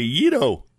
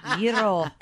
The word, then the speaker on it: gyro: Tim says gyro, and I say yido. Yido. – Yiro. – Because that's how they pronounce it in Manhattan, in New York.